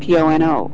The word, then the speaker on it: Ho'oponopono: You can find the website about Ho'oponopono. I'm going to say it like a whole bunch of times now that I'm saying it right. – Perfect.